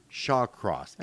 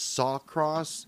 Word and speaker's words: Shawcross: I thought his name was Sawcross, and I get this whole thing done, and then I start looking about, fuck my life. – Well, and I kept telling you, Shawcross.